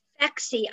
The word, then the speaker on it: Phexxi: Flexi. I think it's spelled P-H-E-X-X-I.